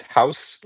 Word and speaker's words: Gous: So you have Andries House, like, G-O-U-S, but, like, pronounced like House.